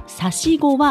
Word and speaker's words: 345: この差し子は数字で 345 と書いて差し子と読みます。